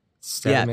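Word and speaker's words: Statamic: Statomic.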